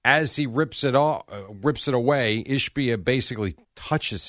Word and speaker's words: Ishbia: Matt Ishbia, I believe is how you pronounce his name. Yes.